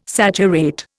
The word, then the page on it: saturate: Indian